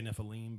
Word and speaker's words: Nephilim: The Nephilim, did I say that right? – I'd say Nephilim. But Nephilim, okay. Yeah.